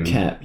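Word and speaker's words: Koepp: Kep, yes.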